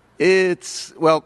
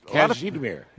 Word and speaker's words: Kazimierz: And the pronunciation was incorrect for Kazimierz? – Kazimierz. – Kazimierz. – Yeah. A lot of people say Kashmir, as if he were the inventor of the sweater, but he's not.